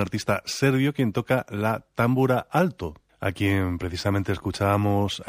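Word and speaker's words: tambura: Y, por ejemplo, la primera támbura, que más bien así lo pronunciarían los húngaros, es la de Aaron Ereditz, que en parte de esta pieza también se ocupa del tambor tapán.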